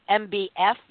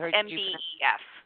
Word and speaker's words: MBEF: You pronounced it, Hilary, MBF. Do you leave out the E, just the EF? – No, it's MBEF. – MBEF, okay, because you could say MBF, because F-E-F-F, anyway.